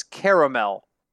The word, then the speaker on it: caramel: The according to Grammarly, which is absolutely infallible, the correct pronunciation is caramel.